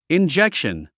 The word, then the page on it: injection: indʒékʃən; インジェクション